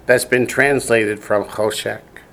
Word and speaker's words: choshek: The spelling is chol, C-H-O, shech, S-H-E-K, choshek. – Choshek.